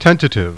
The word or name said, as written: tent